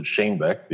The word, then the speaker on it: Shanebeck: Well, that's actually the correct way to say it, but nobody in the U.S. can say it that way. So I pronounce it Shanebeck because it's easier for Americans.